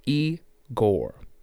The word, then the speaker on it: IGOR: This is Igor. Pronounced Igor.